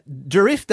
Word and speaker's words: Durif: Uh, Durif Day as well happening this weekend. – Yeah, Durif Day is on Saturday.